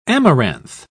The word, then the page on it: amaranth: British English